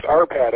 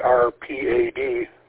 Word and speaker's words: Arpad: W-A-R-C-Y. – Yeah, you're close. The name is ARPAD, actually, ARPAD.